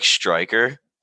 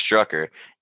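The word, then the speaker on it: Strücker: For some reason, because of like the umlau over the U, like the two dots, it looked like Striker, even though it was pronounced Strucker.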